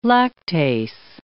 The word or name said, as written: lactase